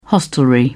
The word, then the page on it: hostelry: British English